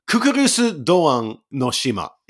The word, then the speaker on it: Kukurusu Doan no Shima: So it's a Kukurusu Doan no Shima is the name in Japanese, and it's a little easier to pronounce if you say it Japanese style.